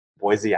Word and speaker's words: Boise: And by the way, I live in Boston and she's all the way in um, Boise, Idaho.